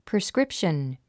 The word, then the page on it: prescription: priskrípʃən; プリスクリプション